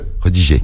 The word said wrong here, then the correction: redirigé: Redirigé. Tu as oublié redigé. Non, c'est redirigé sur Snap.